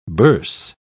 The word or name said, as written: burse